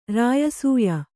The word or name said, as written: ರಾಯಸೂಯ